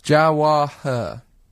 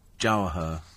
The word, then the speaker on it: Jawaher: What's it called? – Jawaher. – How do you spell that? – J-A-W. – Yeah. – H-E-R. – Jawaher.